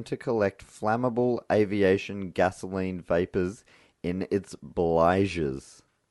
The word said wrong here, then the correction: bilge: How do you spell that? – B-L-I-G-E-S. No, B-I-L-G-E-S. Bilge.